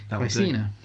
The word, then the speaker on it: Caecina: I'm going to spell it K-Y-S-E-E-N-A. Caecina.